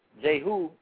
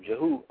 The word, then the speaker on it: Jehu: And so in 2 Kings 9 and 30, Jehu, that's how I say his name, Jehu, a man of God, he was coming to confront Jezebel for idolatries against Israel.